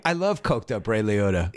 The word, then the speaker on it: Liotta: Liotta. – Liotta. – I'll let you say. – It's one of those. It seems to people pronounce it both ways, but it is Liotta.